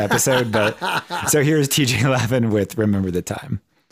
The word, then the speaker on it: Lavin: So here is TJ Lavin. I am saying that right, right? – Yeah. – Lavin.